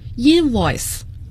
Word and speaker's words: invoice: 这个单词请听众朋友要与 receipt 收据合起来记， receipt 收据 receipt， p 字母是不发音的，发票 invoice，重音在第一个音节，请大家一定要注意 invoice receipt 这两个词都是很有个性的两个词。